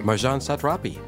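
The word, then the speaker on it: Marjane Satrapi: Marjan Satrapi. – Yes, yeah, see, that's good because you know how to say it better.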